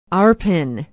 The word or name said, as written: arpin